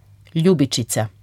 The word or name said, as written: ljubičica